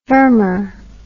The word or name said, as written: firmer